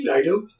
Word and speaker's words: Dido: They did that with an Eminem song in Doe Die. Who's the coming up for Dinwood? – Dodai? Is that her name? – Dido. – I have the syllables backwards.